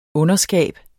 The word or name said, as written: underskab